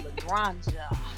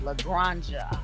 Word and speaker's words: La Granja: It's called La Granja. It's a wine from Spain. You gotta say it right. Like Jumanji, La Granja.